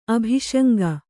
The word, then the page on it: ಅಭಿಶಂಕೆ: abhiśaŋke